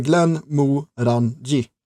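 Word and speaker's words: Glenmorangie: Där de har designat flaskan lite grann som man uttalar det misstänka är tanken bakom, för det står ju Glenn Mo Ranji liksom på fyra rader.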